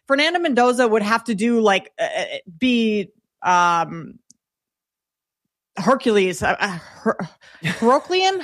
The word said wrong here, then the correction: Herculean: How do you say that word? – Herculean. It would take a Herculean season.